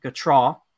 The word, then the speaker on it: Gautreaux: Gautreaux, which I'm definitely pronouncing it right after you called me out last episode. – You got it. You crushed it.